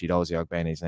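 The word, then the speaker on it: Chiedozie Ogbene: And I must say, Martin, the one pro to me anchoring is that I actually pronounced Chidozie Ogbeni's name correctly.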